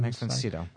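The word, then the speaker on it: Max von Sydow: Is it pronounced Cito, Cito, Max von Max von Cito.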